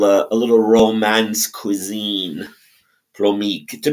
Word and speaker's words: plomeek: It's pronounced plomeek.